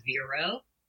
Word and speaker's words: Vero: So, my name's Vero, rhymes with hero.